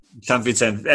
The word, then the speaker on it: San Vicente: Valencia San Vicente. – San Vicente.